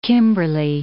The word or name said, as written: Kimberley